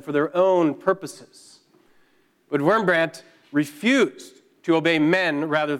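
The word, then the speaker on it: Wurmbrand: Pastor Richard Wurmbrandt, or Wurmbrandt, if you were to say it correctly, was a Romanian Lutheran minister who spent about 14 years in communist prison in Romania.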